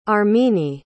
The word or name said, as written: armine